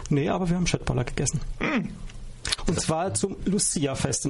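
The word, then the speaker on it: köttbullar: Ich weiß nicht, inwieweit ihr in der schwedischen Kultur, also jetzt mal von Schöttboller und Billigregal abgesehen, bewandert seid. – Ja, immerhin kannst du Schöttboller schon mal richtig aussprechen. Das können ja die meisten IKEA-Kunden nicht.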